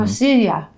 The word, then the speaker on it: pasilla: Water, vinegar, white truffle oil, habanero peppers, pasilla chilies. Is that how you say it? Chipotle spices. And pasilla.